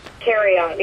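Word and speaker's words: Thieriot: Theriot. Yeah. – Okay. – Nobody does. I don't think he does. Theriot.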